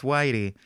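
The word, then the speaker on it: Weide: Today I learned it's pronounced Whitey.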